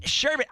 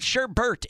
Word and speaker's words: sherbet: It's sherbet. I'm not going to do this with you every single time. You know how it's spelled. There's no R. It's not sherbert. It's sherbet. – Whatever. – Look it up. There's no R. There's no second R.